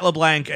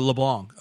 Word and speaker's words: LeBlanc: So we cut to Matt LeBlanc, and he's, or LeBlanc, I'm sorry.